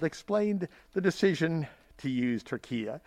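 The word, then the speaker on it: Türkiye: And finally, from Forbes, the United Nations accepted a formal request by the country Turkey to rebrand as Turquia. That's spelled T-U, with an umlaut over the U, R-K-I-Y-E.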